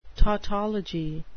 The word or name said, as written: tautology